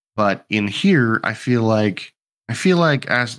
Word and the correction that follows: Askeladd: is that? I never get his name right. – It's Askeladd. – Askeladd, sorry. He saw the potential that he had,